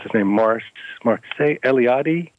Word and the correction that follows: Mircea Eliade: Oh, Mircea Eliade, yes. – Thank you, Mircea Eliade, who says that a dwelling contains the world.